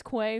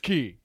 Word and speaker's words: Quay: It's spelled Quay. – It's very confusing. – It's Quay, but the locals are like, ugh. It's Key. It's so obviously Key.